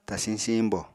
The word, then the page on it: tasiñ siiñbo: apatani